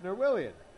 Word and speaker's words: Norwillian: There's no pronunciation guide given for his name, as if the Fort Wayne media staff was like, look, man, it's Norwillian. It's pronounced exactly as it looks.